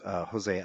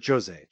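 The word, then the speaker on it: José: One of Conor's biggest fights was against Jose Aldo. Or Jose. – Yeah. – You pronounce the J in the Brazilian Portuguese against Jose Aldo, right?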